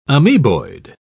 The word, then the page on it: amoeboid: British English